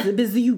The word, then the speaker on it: Bijou: It's French. It's Bijou.